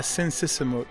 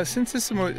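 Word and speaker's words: Sinn Sisamouth: Yeah, Sin Sisimut. Yeah, Sin Sisimut is still considered to be, you know, the ultimate Cambodian singer.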